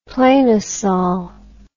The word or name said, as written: planosol